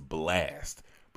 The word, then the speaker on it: Blxst: His name is Blast, but it's spelled B-L-X-S-T.